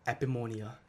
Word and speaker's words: Epimonia: Epimonia.